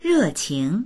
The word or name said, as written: re4qing2